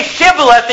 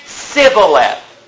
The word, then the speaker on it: Shibboleth: The children of Ephraim, by the way, who's one of the tribes of Israel, the son of Joseph, his descendants, they were told to say Shibboleth, and you know what they said? Sibboleth.